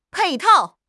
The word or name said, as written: 配套